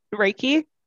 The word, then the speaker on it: Reiki: I did see that you're into Reiki.